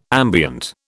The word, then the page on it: ambient: British English